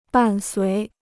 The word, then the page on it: 伴随: bàn suí